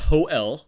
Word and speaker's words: Hole: Now, in Norwegian, it's pronounced Ho-El, which makes sense.